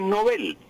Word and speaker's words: Nobel: en sueco es Nobel, y resulta que en español no nos habían aclarado hasta la época de los años 60 que los apellidos pasan al español con la misma pronunciación que tienen en su idioma original.